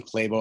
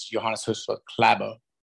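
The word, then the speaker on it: Klæbo: I mean, we were calling English speaking world calls him Klabo, and that's not right either. The real pronunciation is Johannes Hussle Klabo.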